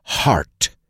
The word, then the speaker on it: heart: Lo que sí me preocupa es la pronunciación de la palabra heart. Es heart y rima con art, arte.